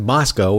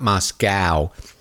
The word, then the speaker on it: Moscow: That's right, I said Moscow, which is the right way to say it, not Moscow.